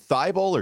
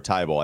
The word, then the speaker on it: Thybulle: Is it Thybulle or Thybulle? I hear people pronounce it differently all the time. – It's Australian, so it's either way.